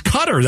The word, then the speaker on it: Qatar: She says the emphasis on the first syllable, Cutter.